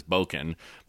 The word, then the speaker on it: Bokonon: and then Bokonon or Johnson, as they actually goes, but the, how they pronounce it on the island is Bokonon.